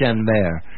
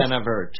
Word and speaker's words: Chenevert: If you want the real explanation, it's a French name. You actually would pronounce it Chenevert. – Uh-huh. – But, you know, we pronounce things phonetically, so Chenevert.